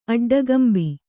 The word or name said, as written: ಅಡ್ಡಗಂಬಿ